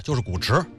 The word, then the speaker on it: Gucci: 你看此前呢，就有一条新闻，说是某著名大品牌，咱们就不点名字，而且这个名字一读啊，我也容易漏怯，因为有的人呢管它叫 Gucci，有人读成是 Gucci，而真正念白了就是古驰。